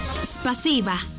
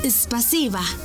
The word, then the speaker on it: спасибо: Спасибо. Una vez más. Спасибо. Y se escribe спасибо. S-P-A-S-I-B-A.